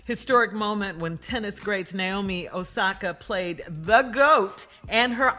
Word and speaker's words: Osaka: Naomi Osaka.